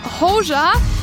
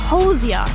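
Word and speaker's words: Hozier: Aber ich habe mir dann ganz altmodisch ein YouTube-Betonungsvideo gerade angeschaut und habe rausgefunden, aha, nein, nicht Hoxha, er heißt Hoxha, so wie Cozier, nur mit H.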